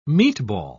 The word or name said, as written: meatball